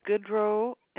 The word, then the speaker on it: Goudreau: My last name is Goodrow, and that is spelled GOUDREAU.